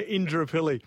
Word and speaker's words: Indooroopilly: Is that how I'm saying it right? – Yeah, Indooroopilly. – It doesn't, phonetically, it says Indooroopilly.